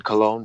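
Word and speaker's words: Colón: And Colon, by the way, is the way you pronounce the last name. Colon is actually the Spanish pronunciation of Columbus or Colombo.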